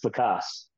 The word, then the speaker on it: LaCasse: Or you're close. It's Lacasse, but I get it all kinds of ways, so it's all good, man.